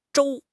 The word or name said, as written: 舟